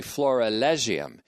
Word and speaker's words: florilegium: I pronounced it wrong before. I said florigelium. It's actually florilegium. F-L-O-R-I-L-E-G-I-U-M.